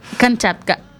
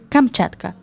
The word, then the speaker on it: Kamchatka: Kamchatka. Lo acabo de pronunciar fatal, que es Kamchatka.